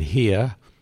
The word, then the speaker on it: Heah: And then it'll be John Primer doing Up and Hea, H-E-A-H, for the proper pronunciation.